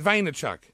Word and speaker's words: Vaynerchuk: Gary Vaybrchuk. – Try. – Gary Vaybrchuk. – Nope. Try again. – Vaybrchuk. – Nope. Go again. – Vaybrchuk. – Nope. Go again. – Vaynerchuk.